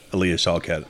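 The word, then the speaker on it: Alia Shawkat: Aaliyah Shawkat.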